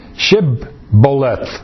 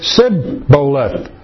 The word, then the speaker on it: Shibboleth: If he said no, then they would say to him, say now, Shibboleth. But he said, Sibboleth, for he could not pronounce it correctly.